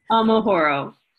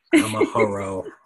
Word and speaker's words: Amahoro: Hopefully I pronounced Amahoro correctly. Is that right? – Amahoro. – Amahoro. Okay.